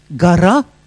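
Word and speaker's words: гора: Et quand on change l'accent tonique, le russe, c'est une langue à accent. Gara, montagne, devient gore. Et ça, ça veut dire le malheur.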